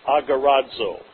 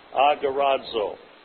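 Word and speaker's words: agorazo (ἀγοράζω): Now that phrase, bought with a price, comes from one Greek word pronounced agorazo. Say it with me. Agorazo.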